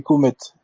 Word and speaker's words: Cometh: c'est Comet. Ça s'écrit Cometh.